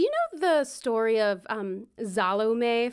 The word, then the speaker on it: Salome: I remember growing up hearing it, Salome. – 100%. When you said the name at first, I was like, that sounds kind of, oh yeah, no, that's how you say it.